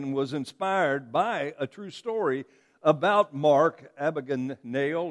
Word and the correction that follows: Abagnale: or whatever his name is. One of these days, I'll learn to say it. I've even got it pronounced or kind of broken up here. Abagnale. There you go. Abagnale.